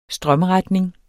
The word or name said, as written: strømretning